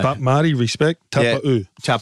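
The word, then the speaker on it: Taupau: I asked him, I said, Marty, did you change your name? He goes, no, you just pronounced it wrong. I said, well, for 10 years, I was Kapow.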